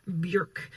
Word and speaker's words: Björk: Apparently, you should pronounce it properly as Björk, rhymes with work.